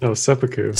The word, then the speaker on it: seppuku: That's seppuku.